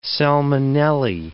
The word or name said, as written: salmonellae